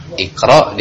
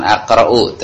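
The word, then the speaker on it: اقرأ: Iqra dibacanya bukan aqra'u tapi iqra, uktub.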